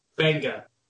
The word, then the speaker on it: Mbenga: It's apparently just Benga. – According to who? – It's Bengay. Basketball reference. It's Benga. Look. – DDA Ilunga Mbenga. Right? – It's just Benga. He's from the Congo. The M is silent.